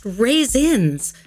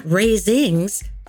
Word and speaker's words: raisins: "It's pronounced raisins, not raisings," Patty corrected.